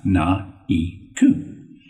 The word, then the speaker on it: Naeku: So I created Naeku, pronounced Na Ku,